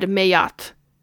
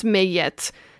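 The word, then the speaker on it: Maat: I have always said Mayat, and apparently it's pronounced Mayet, and I don't think that she minds either way.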